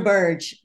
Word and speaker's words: Burge: How do I say his last name? Is it Burge? – Oh, Dr. Burge.